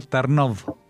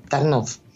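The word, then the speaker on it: Tarnów: Y hoy vamos a visitar Tarnov. – Así es, Tarnov, que los judíos, tú sabes que a veces se modificaban levemente, pronunciaban Torna o Tarno, pero bueno, Tarnov, que se encuentra en Galicia, en Polonia, a algo más de 70 kilómetros al este de Cracovia.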